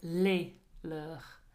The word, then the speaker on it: leurs: So notice there, les leurs. So L-E-S, and then you add an S on the end of leur, but it doesn't change the sound.